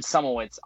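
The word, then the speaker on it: Szumowicz: I am at Austin Sumowitz on Twitter, on YouTube, sorry. So it's my last name is spelled S-Z-U-M-O-W-I-C-Z. I know it's a mouthful, but check me out on YouTube.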